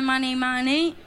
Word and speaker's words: Money Money: Mani Mani come eh, soldi sì in realtà si legge come Money Money però sì.